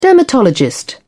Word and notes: dermatologist: British English

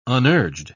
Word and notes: unurged: British English

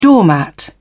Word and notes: doormat: British English

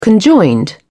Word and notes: conjoined: British English

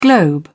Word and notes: globe: British English

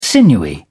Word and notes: sinewy: British English

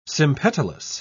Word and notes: sympetalous: British English

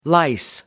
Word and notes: lice: American English

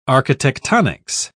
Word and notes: architectonics: British English

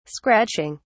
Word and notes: scratching: British English

